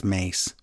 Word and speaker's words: Mace: It's not Mace, by the way. It's Mace. Macho Man.